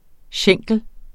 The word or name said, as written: schenkel